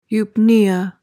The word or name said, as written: eupnea